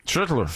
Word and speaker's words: Schlittler: Et il se bat présentement avec un duel impitoyable, je dirais, avec Cam Schlittler. – Schlittler. J'aime ça, mais tu l'as très bien prononcé.